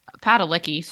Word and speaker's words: Padalecki: Jared Pilecki, you know, Pilecki or whatever his name is. Padalecki, sorry. I don't want to mispronounce his name.